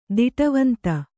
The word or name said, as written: ದಿಟವಂತ